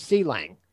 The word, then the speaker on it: Clang: I use MSVC, I use GCC, and I use C-Lang, or Clang, depending on how you want to pronounce it.